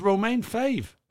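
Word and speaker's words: Romain Faivre: It's Romain Fave. – That is how you say it.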